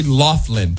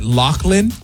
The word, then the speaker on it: Loughlin: In 39 years of my life, I've thought that it was Lori Loughlin. Mm-hmm. And apparently you pronounce it Loughlin? – Yes. – L-A-U-G-H. – L-O-U-G-H-L-I-N.